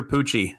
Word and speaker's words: Pucci: It's spelled like Gucci, but with a P. – Which company is older, Gucci or Pucci? – Probably Gucci.